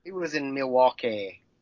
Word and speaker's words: Milwaukee: It's pronounced Milwaukee.